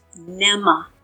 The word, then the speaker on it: Mneme: It's spelled M-N-E-M-E. So first thing, tell us how you actually pronounce this. – You pronounce it like a little girl's name, Emma, with a N in front of it. So it's Nema. Therapy is phonetically how you want to say it.